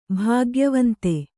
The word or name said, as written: ಭಾಗ್ಯವಂತ